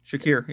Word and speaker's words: Shakir: Give me Shariq or whatever, how you say his name. – Shakir, yeah.